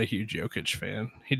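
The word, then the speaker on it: Jokić: And Nikola Jokic is going to be in there because it's Jokic. – You know what? Why not? Because Nikola Jokic is loved Jokic by freaking everybody. – It's a soft J. It's Jokic.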